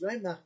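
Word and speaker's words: רומח: So it's pronounced roimach, but there's no Vav inside the word the way it's normally spelled. Why is that?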